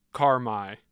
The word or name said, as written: carmi